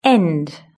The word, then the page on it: end: British English